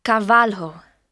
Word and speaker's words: Carvalho: It's Cavalho. – Cavalho. How do you spell that? – C-A-R-V-A-L-H-O. – C-A-R-V-A-L-H-O.